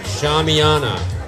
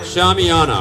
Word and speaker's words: shamiana: Ladies and gentlemen, I have been calling these tents shamanas, but that shows a terrible cultural oversight on my part. They're actually pronounced Shamiana. That right? Shamiana.